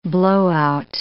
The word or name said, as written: blowout